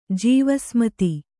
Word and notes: ಜೀವಸಮಾಧಿ: jīva samādhi

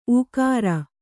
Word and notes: ಊಕಾರ: kannada

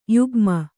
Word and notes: ಯುಗ್ಮ: yugma

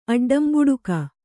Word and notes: ಅಡ್ಡಂಬುಡುಕ: aḍḍambuḍuka

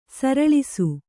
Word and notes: ಸರಳಿಸು: kannada

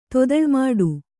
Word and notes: ತೊದಳ್ಮಾಡು: kannada

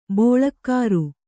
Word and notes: ಬೋಳಕ್ಕಾರು: bōḷakkāru; dial.

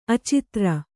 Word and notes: ಅಚಿತ್ರ: acitra

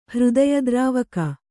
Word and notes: ಹೃದಯದ್ರಾವಕ: Kannada

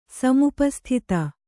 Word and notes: ಸಮೀಪಸ್ಥಿತ: kannada

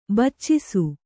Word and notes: ಬೆಚ್ಚಿಸು: Kannada

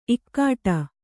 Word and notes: ಇಕ್ಕೋಡು: Kannada

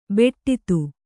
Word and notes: ಬೆಟ್ಟಿತು: beṭṭitu

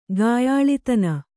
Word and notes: ಗಾಯಾಳಿತನ: kannada

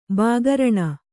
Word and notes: ಬಾಗರಣ: Kannada

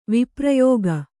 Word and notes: ವಿಪ್ರಯೋಗ: viprayōga

